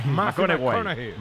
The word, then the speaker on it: McConaughey: Mateo Macones. – Sí. – Un futbolero, le gusta. Hay que ir corrigiendo la pronunciación. – McConaughey.